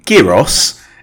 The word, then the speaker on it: gyros: Gyros is pronounced gyros, so G in Greek is a Y, sorry.